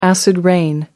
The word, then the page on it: acid rain: American English